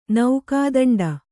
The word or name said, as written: ನೌಕಾದಂಡ